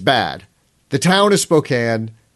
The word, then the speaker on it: Spokane: The name, the, the the pronunciation Spokane really bugs the hell out of me. The city is called Spokane. It's Spokane, Washington. Nobody says Spokane, Washington.